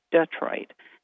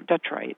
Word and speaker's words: Detroit: People in other parts of the state say Detroit, you know, Detroit.